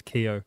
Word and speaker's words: Keogh: And I was at university and I picked up this book and I was looking for a different name because my last name is Keogh, which finishes with a silent GH.